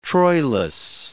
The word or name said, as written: Troilus